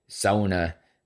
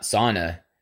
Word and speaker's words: sauna: And whenever I have an opportunity, I try to correct myself and say sauna, not sauna, because it's not how you pronounce it.